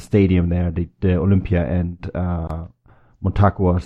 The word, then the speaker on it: Motagua: I'm bound to pronounce this wrong as well, but Motagua? – Yeah, that's pretty good, Bryce.